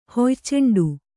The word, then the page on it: ಹೊಯ್ಚೆಂಡು: hoyceṇḍu